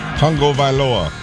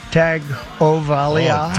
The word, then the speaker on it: Tagovailoa: How does the president say his name? Tua... – Tagovailoa. – You got it? Come on. Come on, we believe in you. Come on. – Tua Tagovailoa. Tagovailoa.